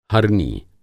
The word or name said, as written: हरनी